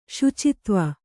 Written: ಶುಚಿತ್ವ

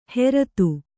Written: ಹೆರತು

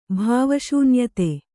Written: ಭಾವಶೂನ್ಯತೆ

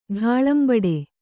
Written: ಢಾಳಂಬಡೆ

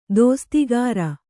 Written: ದಸ್ತಗಿರಿ

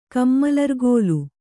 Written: ಕಮ್ಮಲರ್ಗೋಲ

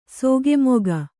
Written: ಸಾಕುಮಗ

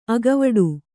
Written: ಅಗವಡು

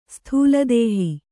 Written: ಸ್ಥೂಲದೇಹಿ

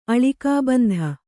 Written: ಅಳಿಕಾಬಂಧ

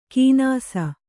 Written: ಕೀನಾಸ

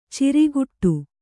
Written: ಚೌರಿಕೊಟ್ಟು

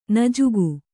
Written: ನುಜುಗು

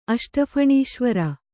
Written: ಅಷ್ಟಫಣೀಶ್ವರ

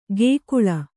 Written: ಗೇಕುಳ

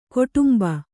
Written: ಗೋಡುಂಬ

